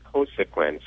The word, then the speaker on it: Cosequin: And then we have her on, I believe it's pronounced Cosequin, C-O-S-E-Q-U-I-N.